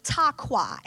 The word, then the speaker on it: taqa: So clapping, the Hebrew word for clapping is taqua, and that's spelled T-A-Q-A.